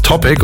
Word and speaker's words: Topic: Und dann hat er sich gedacht, halt stopp, wenn ich es Englisch ausspreche, ist es Topic und ist der geilste Name überhaupt.